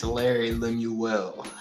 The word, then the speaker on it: Larry Lemuel: I think I might pronounce his mispronounce his name incorrectly, Larry Mill. Yep, it's yep. uh yeah, it's Larry Lemuel.